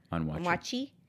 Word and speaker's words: Onwuachi: You have Automix with Jungyeon Park, that's in New York City, and Kwame, I don't know how to pronounce this last name, Onwuachi. – Onwuachi at Keith and Ken in Washington, D.C.